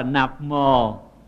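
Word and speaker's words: Namo: Thiệt ra chữ Nam mô này là cái tiếng Phạn, tiếng Phạn ta đọc là Nạp mô.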